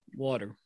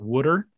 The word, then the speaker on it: water: How do you say that word? – Water. – Thank you. You're a very normal pronouncing Philadelphia area native. It's not Wooder. You got it correct.